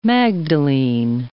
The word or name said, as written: magdalene